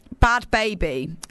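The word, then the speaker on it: Bhad Bhabie: Bob, is that how you pronounce it? Bad Babby? The girl that said, cash me outside, how about that? Is that how you pronounce it? Who knows? Bad Baby. Okay, cool.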